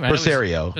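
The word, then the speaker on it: Pelissero: It's not from just a weird, it's from a verified Tom Persillo. Is that how you say his name? – Persario. – Tom Persario. Yeah, that's where I saw it, yeah.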